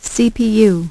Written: CPU

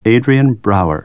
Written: Brouwer

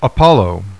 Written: APOLLO

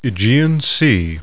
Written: AEGEAN

